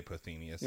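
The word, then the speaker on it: Pothinus: I would say Pothinius is how you say that name, yes.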